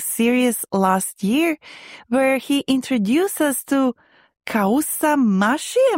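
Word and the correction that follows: kousa mahshi: Am I saying that right, Ryan? – Yeah, kusamashi.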